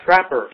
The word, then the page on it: trapper: American English